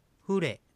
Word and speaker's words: フレ: はい、最初にアクセントが来るので注意してください。フレですね。